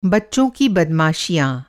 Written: बदमाशी

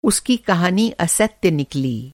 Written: असत्य

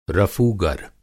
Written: रफ़ूगर

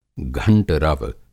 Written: घंटरव

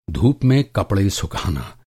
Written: धूप

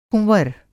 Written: कुँवर

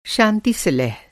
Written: शांति-सुलह

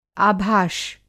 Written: आभाष

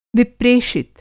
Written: विप्रेषित

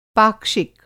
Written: पाक्षिक

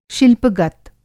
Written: शिल्पगत

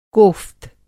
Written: कोफ़्त

